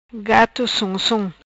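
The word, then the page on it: Gato Som Som: patua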